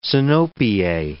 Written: sinopie

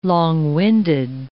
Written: long-winded

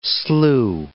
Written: slough

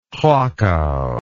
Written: krakau

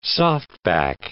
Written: softback

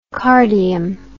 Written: cardium

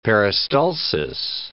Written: peristalsis

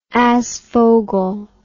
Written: aasvogel

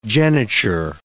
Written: geniture

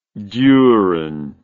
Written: duren

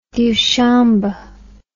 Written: diushambe

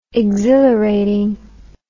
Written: exhilarating

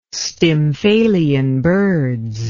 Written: stymphalian birds

